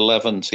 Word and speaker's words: Levant: I don't know if I'm pronouncing that correctly. – Blood of the Levant. Yeah. I mean, that's my favorite track as well, really. – Yeah. Blood of the Levant. Okay. You're right.